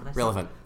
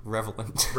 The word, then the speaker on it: relevant: Relevant. – Relevant. – There you go. – How did I say it? – Revelant. – Revelant.